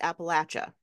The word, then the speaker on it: Appalachia: If you're not from the Appalachia area, that's how you say it.